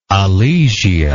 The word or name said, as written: alesia